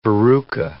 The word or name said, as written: verruca